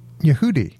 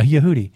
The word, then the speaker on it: Yehudi: Yehudi. – Yeah, Yehudi.